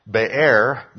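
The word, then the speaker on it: Be'er (באר): Be'er means well. So, and Sheba means oath. So, well of oath. Okay. And so, just have it clear. What looks like beer is pronounced Be'er, and beer means well.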